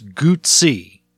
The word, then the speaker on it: Guzzi: The double Z is exactly the same as pizza. Instead of pizza, it's Guzzi. That is it. That is the entirety of the trick behind the pronunciation of this, and you no longer have any fucking excuse.